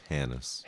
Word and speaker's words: Hannes: Hannes. – Hannes. H-A-N-N-E-S.